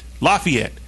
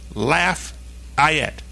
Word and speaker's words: Lafayette: Texter here in the reference to that general whose name is spelled L-A-F-A-Y-E-T-T-E. It's okay. That's how you spell his name. The way you pronounce it, according to this texter, is Lafayette. Laugh, I-E-T-T-E. Lafayette. And then, with a big emoji of a crawfish, it looks like the texter says I-E. So I-E on that. That is definitely how you're going to pronounce that word down in Louisiana, for sure. It's Lafayette.